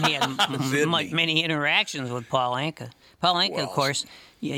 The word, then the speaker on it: Paul Anka: Who was it that couldn't say his name, so he called him Palunka? He couldn't say Paul Anka for some reason.